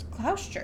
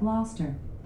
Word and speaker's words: Gloucester: It's like Worcester, and I'm not joking. – It's Gloucester. It starts with a G. – I know. Alexa, how do you say Gloucester? – I pronounce that Gloucester, but I'm always working on how I say things and I might not have it right.